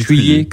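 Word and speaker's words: Cuiller: Cuillère, on dit Cuillère.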